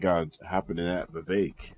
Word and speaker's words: Vivek: Vivek doesn't just rhyme with cake.